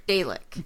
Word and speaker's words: Dalek: it's pronounced Dalek. – Dalek.